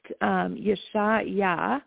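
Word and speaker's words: Yeshayahu: So in Hebrew, in the Hebrew language, which is the language that the Old Testament was penned in, it would be Isaiah would be pronounced Yeshayah,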